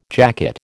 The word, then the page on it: jacket: American English